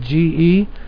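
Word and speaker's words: ge (γῆ): The earth is the word here, the Greek word ge, is spelled G-E, pronounced ge.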